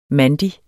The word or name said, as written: mandig